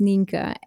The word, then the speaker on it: Nienke: So I'm from the Netherlands, and my Dutch name, so my real name, is Ninka, and this name is impossible to pronounce internationally.